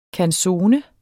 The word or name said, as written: canzone